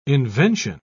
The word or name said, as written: invention